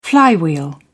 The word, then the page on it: flywheel: British English